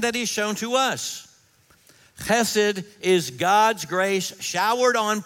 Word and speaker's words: chesed: The Hebrew word is chesed. Chesed. Say that with me. Chesed, chesed, chesed. That's the way it's pronounced.